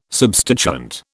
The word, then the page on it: substituent: British English